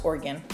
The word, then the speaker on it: Oregon: And just so you guys know, it's pronounced Oregon. – Yeah. – Like an organ, like you have in your body.